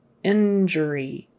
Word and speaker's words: injury: It's actually just going to be a It's going to be an ER kind of a sound. Injury, injury. And the stress is on the first syllable, injury.